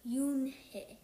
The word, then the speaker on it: Unhei: "No, no," Yoon-hae corrected. "It's spelled U-N-H-E-I. It's pronounced Yoon-hae."